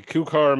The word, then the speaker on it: Koucar: Kocar? K-O-U-C-A-R. – A Kocar Management.